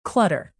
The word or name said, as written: clutter